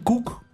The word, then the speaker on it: Kuc: El nombre, la URL del canal es Damián Cook. Cook se escribe K-U-C, sí. – No es como cocinar. – Sí. – K-U-C, ok.